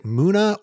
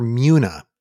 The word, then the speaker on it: Muna: Whether you pronounce it Muna or Muna, M-U-N-A is the way you spell it.